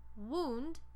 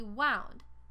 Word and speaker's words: wound: Un homógrafo, recuerda, es donde dos palabras tienen la misma ortografía, pero las pronunciaciones diferentes, como wound y wound. Si escriben los dos como W-O-U-N-D, pero cuando se dice wound, significa una herida. Y cuando se dice wound, significa el tiempo pasado del verbo inglés to wind.